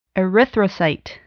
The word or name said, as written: erythrocyte